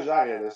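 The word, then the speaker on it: Jágr: Bueno, Yo, yo, lo pronuncio Jagger, se escribe J-A-G-R.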